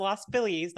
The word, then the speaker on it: Los Feliz: It is pronounced Los Feliz, not Feliz. That's how you can tell when someone is not from LA. – Yeah, I learned the hard way not to say Los Feliz. I've learned the hard way. – It's Feliz. That's how you say it. – Yeah, you know, like Feliz Navidad, like in the Spanish language, Feliz.